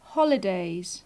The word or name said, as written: holidays